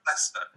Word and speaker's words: Leicester: Now, you are the deputy director of the Digital Culture Institute at the University of Leicester. Is that pronounced correctly? – It's actually pronounced Leicester. And American people always struggle with this word. – Leicester. Okay. – It's pronounced Leicester.